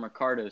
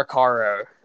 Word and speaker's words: Recaro: I guess I've been saying it wrong. I call them Recaro seats. – They're Recaro seats.